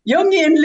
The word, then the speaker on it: Jungian: Jungian lens.